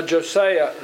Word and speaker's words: Josiah: And there was a tremendous revival under Josiah. Is I'm saying that right? Josiah. Thank you.